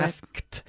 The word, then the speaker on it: asked: La pronunciación del pasado de este verbo, aunque sea un verbo regular, suele provocar problemas. ¿Por qué? – Porque la pronunciación es difícil. Sí, asked. – Eso es. Y porque vuestra T, la T española, es bastante floja.